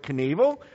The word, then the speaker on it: Knievel: What rhymes with Evil Knievel? Oh, well, evil rhymes with Knievel. Yeah, okay, Awful Knoffel, Evil Knievel. Evil spelled E-V-E-L.